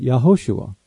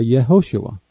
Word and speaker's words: Yehoshua: Those living in Israel today pronounce it Yahoshua or Yehoshua.